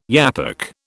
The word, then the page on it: yapok: British English